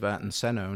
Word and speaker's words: Ayrton Senna: I mean, you know, we can all think back to the days of Ayrton Senna and how long ago was that? – He says it right. – Yeah. Yeah. – Oh, Ayrton Senna?